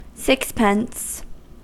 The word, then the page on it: sixpence: American English